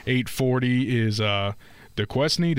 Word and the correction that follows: Duquesne: Did I say that right? – Duquesne, thank you, and Illinois, and then the game, and then tomorrow's games wrap up with Oregon and Creighton at 9:40.